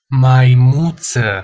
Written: maimuta

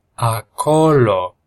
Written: acolo

